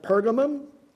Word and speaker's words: Pergamos: The church at Pergamos, sometimes pronounced Pergamum, there the Lord said, "That's where Satan's seat is.